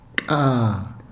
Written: kaa

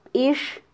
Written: bix

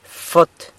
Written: fot

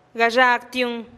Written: gaxagtiun

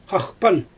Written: xaxpan

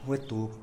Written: wetub

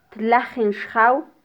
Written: tlajin xjaw

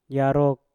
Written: yarok

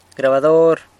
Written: grabador

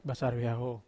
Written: basar vehaho

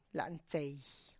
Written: lantsey

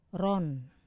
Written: ron

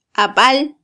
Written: abal

